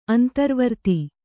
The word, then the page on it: ಅಂತರ್ವರ್ತಿ: antarvarti